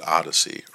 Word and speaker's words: OHDSI: Later, in 2014, it was adopted by the Observational Health Data Sciences and Informatics Initiative, pronounced ODYSSEY or OHDSI.